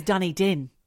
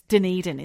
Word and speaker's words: Dunedin: I always thought it was Dunedin. – What? – Obviously, that's not how you pronounce it. I don't know much about New Zealand. – Like she's from Cork. Like she's from Cork in Ireland. But Dunedin. – Dunedin.